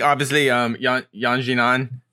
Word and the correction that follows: Yan Xiaonan: I, how do we say her name again? Yan Nan, Yan Xiaonan. Nan, Yan Nan. Yeah. Ooh, how do you say it? Esther Lin with the guest appearance. Xiaonan. – Xiaonan.